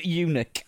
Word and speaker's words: Yunick: Henry Smokey Eunuch. – I wish it just said Smokey. – Eunuch. – It's spelt Y-U-N-I-C-K. – Look at how it's spelled. Can't call him a eunuch.